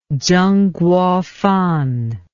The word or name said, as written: tsengkuofan